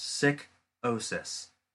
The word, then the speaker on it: Psychosis: Who is well known as Morbid Psychosis, pronounced Sick-osis. Stuffed to promote the short film he just did, Stevie Stone in Mad Child Show, is coming up.